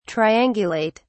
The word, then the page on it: triangulate: British English